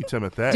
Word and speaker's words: Timothée: Yes, but his first name is spelled T-I-M-O-T-H-E. – Exante Go. – Like, you know, Beyonce. – I mean, I don't know. – E, so it should be Timothée.